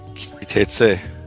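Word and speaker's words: Ki Teitzei: Just pronounce it for me. What is it? – Kitetzeh. – There it is.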